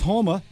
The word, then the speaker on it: Homa: Max, I believe it's pronounced Homa, defeated Tony Finau.